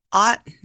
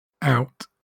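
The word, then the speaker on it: aught: Well, it's ought not, right? Is that not how you say them? – You'd think so, wouldn't you? Yeah. Right. The first one is pronounced out. – Okay. – And the other one is pronounced nout. – Okay. So out and nout instead of ought and not.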